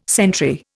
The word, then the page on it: sentry: Indian